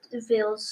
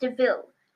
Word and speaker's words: de Vil: I really liked how it explained Cruella de Vil's story and why she was so mean to Dalmatians. And by the way, it's pronounced de Vil, not devil.